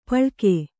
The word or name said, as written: ಪಳ್ಕೆ